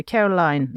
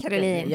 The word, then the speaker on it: Caroline: Vi kunde se först Albert, förstinnan Charlene, deras barn, tvillingarna Jacques och Gabriella, men även Alberts två systrar, prinsessorna Caroline, nej hon uttalar det inte. – Caroline. – Ja tack.